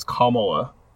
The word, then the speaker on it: Kamala: I thought it was Kamala, but I guess I was correct. It's Kamala. Like, comma and then law. – Interesting. Kamala.